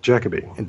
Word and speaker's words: Jacobi: Derek Jacobi.